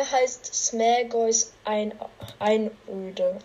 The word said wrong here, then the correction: Smaug: Also Smaug. den Namen kann ich nicht richtig aussprechen, aber. Smaugs Einöde, ja.